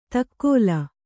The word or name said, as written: ದಕ್ಕಲು